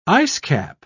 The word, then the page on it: icecap: British English